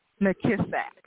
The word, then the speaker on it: McKissack: So how do you pronounce your last name? – McKissack. – McKissack, as it is spelled.